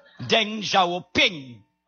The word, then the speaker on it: Deng Xiaoping: Deng Xiaoping.